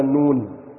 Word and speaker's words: نون: يعني مثلا نون هي تبصلها في المصحف ايه حرف، لكن هنا نطقها ده انت بتنطق كلمه مكونه من ثلاثه احرف. ايه الاحرف؟ نون مضمومه، واو مديه، ونون ساكنه. عليكم الصلاه والسلام.